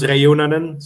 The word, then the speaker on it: Reunanen: Pronunciation is tricky with some European names, but his last name is pronounced Reunanen, so...